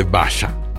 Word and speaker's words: Basia: ¿Te acuerdas de Basha? Se escribe, su nombre se escribe Basia, su nombre completo es Basha Trestelewska, cantante polaca.